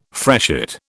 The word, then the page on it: freshet: British English